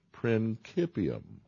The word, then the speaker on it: principium: Now, the Latin phrase for this idea is that Revelation is principium. That's how you say that word. P-R, for you guys that are listening without the PowerPoint, principium is spelled P-R-I-N-C-I-P-U-M, principium.